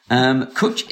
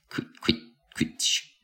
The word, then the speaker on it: cwtch: By the way, rhymes with butch, it says here. I love the word butch. Kutch is a special hug or a cuddle in Welsh. Actually, the correct way to spell it is C-W-T-C-H. Kutch.